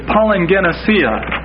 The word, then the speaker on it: palingenesia: It's palingenesia. Palingenesia. And that's spelled P-A-L-I-N-G-E-N-E-S-I-A.